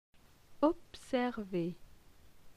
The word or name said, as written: Observer